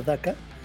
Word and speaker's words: Dakou: El primer capítulo se llama Daka, es una palabra china que se escribe D-A-K-O-U, Dakou, pero se pronuncia Daka.